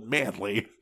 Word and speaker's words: Manley: It's spelled with an E in between the L and Y, but it's still Manley.